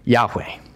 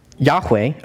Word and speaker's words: Yahweh: So whenever you see capital L-O-R-D in your Old Testament, that's actually code word for God's personal name, Yahweh, is the Hebrew way to say it, or the English way of pronouncing it is Jehovah.